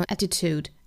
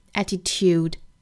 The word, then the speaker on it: attitude: 音标里面呢 attitude 是英音，我们看到 t 和 u 这两个字母之间呢有一个耶这样的一个辅音，但是美音的话是没有的，美音直接读成 attitude attitude，没有这个耶的音。英音的话更近似于 tie tie attitude tude，美音的话呢是 tude。